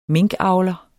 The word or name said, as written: minkavler